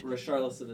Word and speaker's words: Richarlison: Who is Richarlison? And am I saying that right? – Richarlison is correct.